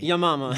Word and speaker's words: Yamama: but the battle was called Yamama. – Yeah. – Y-A-M-A-M-A. – Yamama.